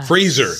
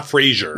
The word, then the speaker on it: Fraser: Fraser, not Frasier. Mm-hmm. We have learned to say his name correctly.